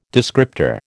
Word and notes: descriptor: American English

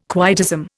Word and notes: quietism: Indian

